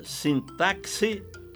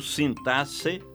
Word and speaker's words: sintaxe: Você pronuncia sintaxe ou sintasse? Que eu saiba, apenas o Aurélio abona ambas as formas. Prefiro acompanhar o Ais, Caldas Raulete e o Volpe, que registram apenas a pronúncia sintaxe.